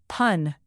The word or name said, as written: pun